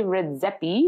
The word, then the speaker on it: Redzepi: Okay, but the restaurant itself is a 3 Michelin star restaurant run by Rene Redzepi. Is that how you say it? – Mm-hmm.